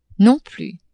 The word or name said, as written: non plus